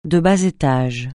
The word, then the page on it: de bas étage: francais